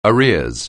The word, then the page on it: arrears: anglais